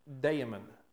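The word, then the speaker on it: daemon (δαίμων): But the Greek word that we get this word from is pronounced daemon.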